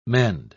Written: mend

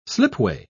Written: slipway

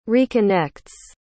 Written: reconnects

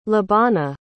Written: lebana

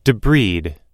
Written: debride

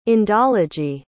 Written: indology

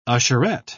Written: usherette

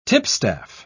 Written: tipstaff

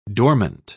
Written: dormant

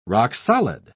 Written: rock-solid

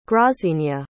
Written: grazinia

